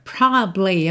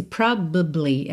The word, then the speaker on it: probably: A palavra de três sílabas que só soam duas. Às vezes, né? Às vezes a gente até pode ter probably, probably, tá? Mas, frequentemente, especialmente em letra de música, quando você tem pouco tempo ali, esse probably, essa sílaba do meio, prob, que não é ba, né? Esse a não aparece. Então, é probably. Some, porque é como se você juntasse esses dois B's. Como o A praticamente não aparece, os dois B's se juntam.